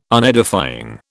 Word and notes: Unedifying: British English

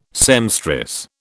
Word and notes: seamstress: British English

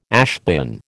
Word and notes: ashbin: American English

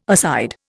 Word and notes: aside: Indian

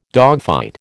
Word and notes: dogfight: American English

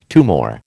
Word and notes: tumour: American English

